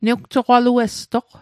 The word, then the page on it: newjoqaluestoq: Mi'gmaq